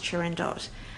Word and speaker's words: Turandot: I managed to get them to go see Turandot, which I'm reliably informed is pronounced Turandot.